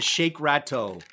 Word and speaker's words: shakerato: So it's S-H-A-K-E-R-A-T-O, shakerato. – Shake. Rat. Oh, you got okay, it. Shake right. Rat-o. Yeah, yeah. And on that note, I'm going to shake Rat-o.